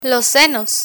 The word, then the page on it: senos: spanish